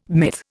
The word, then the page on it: myth: Indian